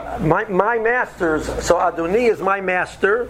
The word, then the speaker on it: Adonai: When we pronounce the Shem's name, so the Mishabura points out that the correct pronunciation should be Milara, so you stress the last syllable. At the same time, a person has to be aware that the middle syllable, written Dalad, it should be pronounced with an O sound, not with an I sound or with an E sound. So a person says Adonai.